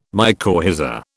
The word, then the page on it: mycorrhiza: British English